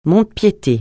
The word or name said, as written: mont-de-piété